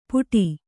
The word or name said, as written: ಪುಡಿ